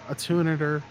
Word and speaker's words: attenuator: Tube-amp attenuator...